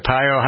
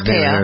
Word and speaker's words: Arpaio: Joe Arpaio's, is it Pio? How do you pronounce Arpaio.